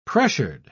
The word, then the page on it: pressured: British English